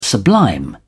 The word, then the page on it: sublime: British English